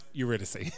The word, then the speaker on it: Eurydice: Also, it's pronounced Eurydice.